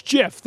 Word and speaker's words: GIF: but I will always say GIF. – It's the new tomato-tomato for this generation. – Well, but tomato's wrong, and GIF is wrong, okay? So just say it right. It's GIF.